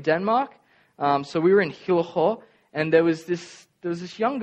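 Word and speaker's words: Hillerød: We stayed in the little town of Hillerho. They say it like that. It's got a, like a bit of phlegm in your throat. Hillerho, it's like one of the circles with the line through it.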